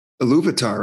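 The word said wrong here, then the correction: Ilúvatar: That's not how it's pronounced by the guy who reads it. It's Iluvatar. – Gosh, see? – Like it's still Ilu, but it's Vatar.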